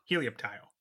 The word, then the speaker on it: Helioptile: So it's Helio, like, because it helps, Halo. And Tile, so Helioptile, Helioreptile. – Yep. – Helioptile.